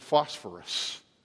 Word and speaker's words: phosphorus: The word for morning star is, it's in English we would pronounce it phosphorus. Phos is the word light, and phero is the word to carry. The one who carries the light, the phosphorus.